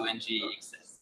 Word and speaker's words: NGXS: Go download and add a star to NGXS. – Oh! – Because it rhymes with success.